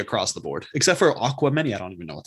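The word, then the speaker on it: Aquemini: Aquamany. Like Aquarius, Gemini. Aquamany.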